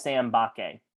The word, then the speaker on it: Mbake: Apparently the M is silent. Apparently it's just Sam Mbake.